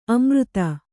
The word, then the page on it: ಅಮರ್ದು: kannada